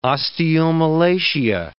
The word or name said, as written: osteomalacia